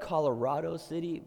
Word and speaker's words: Colorado City: And so I heard about this opportunity to preach at this church in a town about an hour west of Abilene called Colorado City. It's spelled Colorado City, but the secret is it's pronounced Colorado City. I learned that the first day I was there.